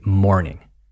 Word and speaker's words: mourning: It's pronounced just like la mañana, okay? Mourning.